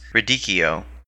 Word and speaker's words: radicchio: But it's actually pronounced radicchio.